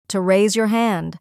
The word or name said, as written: to raise your hand